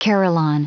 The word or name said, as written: carillon